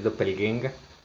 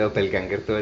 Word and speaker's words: Doppelgänger: Ajá, Sí, en, en, en, en correcta pronunciación alemana es doppelgänger. Nos regañaron por decirle doppelganger todo el tiempo una vez. un, catedrático de alemán, además, que era doppelgänger.